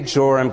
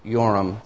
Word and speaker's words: Joram: 2 Kings 9:22, when we say Joram because it's spelled that way in English. It's actually Yoram, the way it's pronounced. When Yoram saw Jehu, which would be Yehu, he asked, have you come in peace, Jehu?